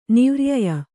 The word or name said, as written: ನಿವ್ರ್ಯಯ